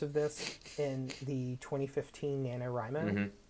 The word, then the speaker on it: NaNoWriMo: I said NaNoWriMo for the longest time, but apparently someone corrected me, and the actual pronunciation is NaNoWriMo. – Yeah. – Which makes sense, because that's, like, it's abbreviating right.